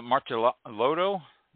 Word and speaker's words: Martellotto: So, but is it Martellotto, your last name? – Yeah, Martellotto is the American pronunciation that works.